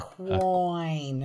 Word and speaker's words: quoin: Actually, interestingly enough, though, Kathy, the normal first pronunciation is coin. – Oh, is it? – Yeah. Isn't that interesting? – Is that coin? – No, the first pronunciation they have in Merriam-Webster, Merriam-Webster, is coin, spelled Q-U-O-I-N.